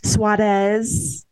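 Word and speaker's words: Suárez: Suarez with the rolled R. – I was trying to, our friend Mia, we were arguing about how you say it. So Jamie Steyer yeah. – says it. So I can't roll my R's. So Suarez. Pretend I'm rolling my Suarez. So it's like a three syllable Suarez.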